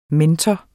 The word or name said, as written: mentor